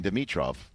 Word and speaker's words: Dimitrov: Because there is no meat in Dimitrov. There is no meat in Dimitrov because he's a vegan. – Nice. – That's the way to remember how to pronounce his name.